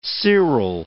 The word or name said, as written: seral